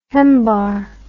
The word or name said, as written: hembar